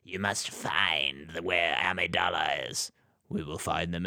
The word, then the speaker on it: Amidala: Amidala? – Amidala.